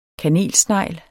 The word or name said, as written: kanelsnegl